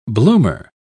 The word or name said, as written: bloomer